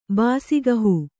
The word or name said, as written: ಬಾಸಿಗಹೂ